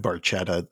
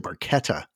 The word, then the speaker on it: Barchetta: It's funny about Red Barchetta because they mispronounced it. It's actually Barchetta. That's how you say the name of the car.